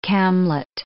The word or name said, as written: camlet